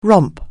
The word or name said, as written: romp